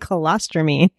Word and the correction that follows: cholestyramine: but it's actually cholestyramine.